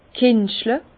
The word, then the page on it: kentschle: Bas-Rhin; Herrlisheim